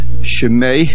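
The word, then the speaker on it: Shemei (שמה): The word Shemei, the hey has a mapik, that means that the hey should be pronounced.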